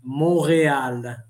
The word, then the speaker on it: Montreal: Montreal, non si pronuncia la T. Non c'è la T.